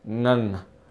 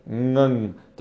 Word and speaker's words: Ngan: I mean, her, like, Ngan, that's a really popular name, pronounced Ngan, I believe. Ngan. The first Ng is Ng, like we have in English, and then the second N is N, so it's Ngan, I think.